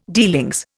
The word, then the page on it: dealings: Indian